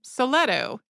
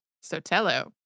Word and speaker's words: Sotelo: Throughout the episode, you pronounced the Spanish missionary's name Soleto. It is, in fact, Sotelo.